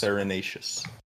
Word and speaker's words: farinaceous: And Pynchon uses the word Farinaceus? I think that's how it might be pronounced. – Farinaceous. – Farinaceous.